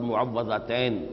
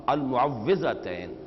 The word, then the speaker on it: المعوذتین: اصل میں تلفظ میں کچھ مشکل ہوتی ہے, عام طور پر معوذتین یہ غلط العام میں اس کا نام مشہور ہو گیا ہے, لیکن اصل میں المعوذتین واو کے زیر کے ساتھ.